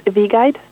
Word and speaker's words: V-Guide: And this is why the Vegan Society have launched an app today called V-Guide. So it's spelled as V-E and then guide, and it's available on Android and iOS devices from today.